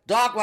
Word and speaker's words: Dakhma: The song name is Dakhma. It's D-A-K-H-M-A. D-A-K-H-M-A. Dakhma. – Sounds right.